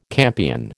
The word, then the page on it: campion: American English